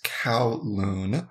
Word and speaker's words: Kowloon: It's Kowloon. – Interesting. – So The cow, it's spelled K-O-W-L-O-O-N, Kowloon. Okay, so O-O-L? When I, O-O-N, K-O-W.